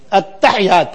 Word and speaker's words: التحیات: التحیات پڑھتے ہیں، ہا کو ساکن، یا کو مخفف، اور اس کے علاوہ بہت کچھ ملے گا.